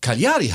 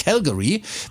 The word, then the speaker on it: Cagliari: Das heißt ja nicht Calgary, weil man kommt von Calgary auf. Wie hast du es gerade gesagt? – Cagliari, ja. – Nee, ist es aber nicht.